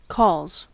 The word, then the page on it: calls: American English